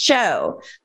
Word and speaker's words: Tcho: In the Bay Area, and it's national, it's a chocolate, and it's spelled T-C-H-O, and it's pronounced cho, like chocolate, C-H-O, but it's T-C-H-O.